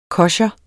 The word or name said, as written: kosher